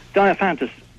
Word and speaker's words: Diophantus: Diophantus. – Diophantus.